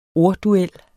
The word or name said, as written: ordduel